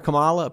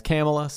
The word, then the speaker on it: Kamala: I've been calling her Kamala. Apparently that's not how you say her name. It's Kamala.